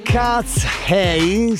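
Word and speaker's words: KATSEYE: Cats Hey, si pronuncia così, non sono sudcoreane, pardon, ma è una girl group di LA, Los Angeles.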